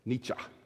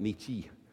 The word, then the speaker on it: Nietzsche: That's how you pronounce his name. I looked it up. Nietzsche. Not Nietzsche. Nietzsche.